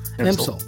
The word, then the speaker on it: Empsall: Empsall.